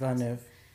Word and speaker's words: Villeneuve: Okay, so my first one, I will actually go with Denis Villeneuve. I don't know how to say it. – Villeneuve.